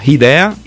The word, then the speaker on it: Hidea: Idea. – Semplicemente così. – Idea all'italiano. Bello, limpido, idea all'italiano.